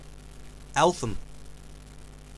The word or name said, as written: Eltham, Victoria